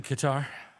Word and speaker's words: Qatar: In Qatar. – Ta ta ta ta. – Pronounced gutter, but okay.